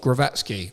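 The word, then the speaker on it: Głowacki: I mean, obviously, Riakporhe now fighting uh, Glowacki. That I believe that is the correct pronunciation from when he boxed Lawrence, and I got yeah.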